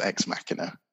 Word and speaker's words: Ex Machina: Which I want to say Ex Machina, but I think is Ex Machina, which slightly annoys me. – Yeah, I think the correct pronunciation is Ex Machina, but...